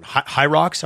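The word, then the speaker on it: Hyrox: High Rocks, am I saying that correctly? – Yes.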